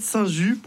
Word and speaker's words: Saint-Just: On va parler de Saint-Just, prononcé ainsi par les Lyonnais, le 13e évêque de Lyon au 4e siècle.